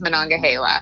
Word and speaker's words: Monongahela: I pronounce it Monongahela, but my mom, I asked her the other day, and she says Monongahela.